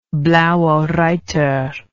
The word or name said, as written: blauereiter